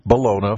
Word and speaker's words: bologna: And down there, they pronounce it bologna. – Uh-huh. – All right? Not bologna. Come on, B-O-L-O-G-N-A, bologna. Where's the knee? There's no knee in there.